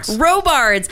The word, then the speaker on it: Robards: Robards.